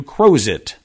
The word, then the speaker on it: Crozet: I would have thought that in Virginia, Crozet, even if it was originally pronounced with the French accent, would have had over time turned into Crozet. But no, no. In Oklahoma, Prague became Prague, but in Virginia, Crozet stayed Crozet.